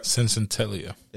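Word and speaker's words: Senzatela: You got Antonio Cincentelia. I said that right? – Cincentelia. – There we go.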